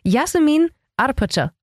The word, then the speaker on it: Yasemin Arpaci: Jasmin Arpacha, auch richtig ausgesprochen. Auch perfekt, ja.